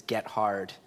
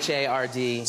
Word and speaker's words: Gethard: You can pronounce it any way you want, as long as you don't pronounce it phonetically, which is Gethard. It's Gethard.